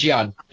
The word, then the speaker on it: jian: Other than the blowgun and the Uzi, he had the Chinese Jan sword. – Mm-hmm. – Chinese Jan sword? – The straight blade. – Okay. It's sword. It's Jian. Yeah. – Maybe I'm pronouncing it wrong. J-I-A-N.